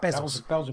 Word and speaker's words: Bezos: How do you say his name? Bezos, is it? Bezos. Is it Bezos or Bezos? Bezos. – It's called Bezos.